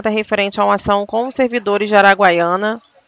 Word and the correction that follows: Araguaína: É Araguaiana que se fala mesmo, né? Tô falando certo. – Araguaína. – Araguaína. Ah, sim, tem um acento aqui.